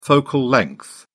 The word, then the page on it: focal length: British English